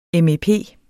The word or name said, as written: MEP